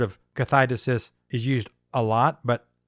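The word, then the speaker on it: kathidesis (καθαίρεσις): Well, if we look at the original meaning of the word pulling down, casting down, yanking down the stronghold, the word is, it's a long word, it's four syllables. It's a Greek word called kathidesis. And that word is the word that Paul used in 2 Corinthians 10:4, kathidesis.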